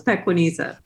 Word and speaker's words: Pequeneza: You are very close. Pequeneza.